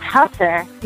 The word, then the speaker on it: Husser: Is it pronounced Husser or Hooser? – Oh, it's pronounced Husser.